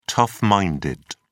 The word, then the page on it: tough-minded: British English